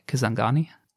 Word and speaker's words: Kisangani: Do I pronounce that correctly, by the way? Kisangani? – You are, you are.